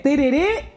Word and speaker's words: tereré: Tereré y con un último acento en la E.